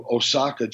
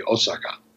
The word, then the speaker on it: Osaka: Yeah, Japanese typically when you look at a Japanese word, there's no you know in in English we tend to uh, emphasize syllables yakuza, you know, Huntsville. In Japanese, it's just they treat every syllable the same. So it's usually like in, in English, people say, oh, you're going to Osaka, Japan. And in Japanese, it would be Osaka. So there's no emphasis on syllables, but that's just me.